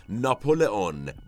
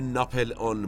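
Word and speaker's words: Napoléon: تلفظ درست اسم بازیگر اصلی این قسمت از مبرخ هست ناپولئون بوناپارت اما در ادبیات مصطلح سیاسی ایران به ناپلئون بوناپارت معروفه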